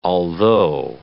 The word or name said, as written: altho